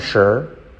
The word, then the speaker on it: sure: Uh, I'm not sure. I'm not sure. I'm not sure. La T casi no se dice. La S como una SH, ¿vale?